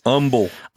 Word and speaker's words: Humble: Humble. – Humble, don't pronounce the H.